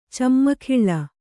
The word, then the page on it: ಚಿಮ್ಮುಗೊಳ್ಳು: cimmugoḷḷu